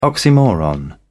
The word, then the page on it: oxymoron: British English